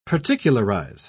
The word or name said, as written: particularize